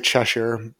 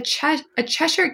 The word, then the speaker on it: Cheshire: I always just say Cheshire, which is I think how the English would pronounce it.